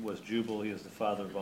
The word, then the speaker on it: Jubal: J-U-B-A-L. – Genesis 4. – Jezebel? No, not Jezebel. did human music begin with Jubal? Did I pronounce that right? – Yeah. – Yes. Jubal.